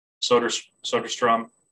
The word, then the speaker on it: Soderstrom: Let's see, I've worked with Aramis, I've worked with Soderstrom, that's how you say his name, Tyler, and Colin Thoreau.